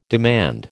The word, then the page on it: demand: American English